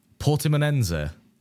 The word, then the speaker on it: Portimonense: Portimonense.